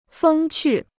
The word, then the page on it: 风趣: fēng qù